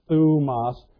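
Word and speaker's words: thumos (θυμός): The first one we have up here, and I've got thumos. See that Greek word? It's spelled in English there. That's what those Greek letters sound like, thumos.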